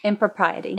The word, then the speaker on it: impropriety: Then it says the behavior, manner, or appearance intended to avoid improperty or indecency. – Impropriety. – Impropriety. Thank you.